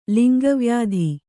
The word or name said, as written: ಲಿಂಗವ್ಯಾಧಿ